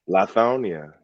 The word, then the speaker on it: Lithonia: And let's say the first stop is in Lithonia. Did I say that right? – Lithonia, yes.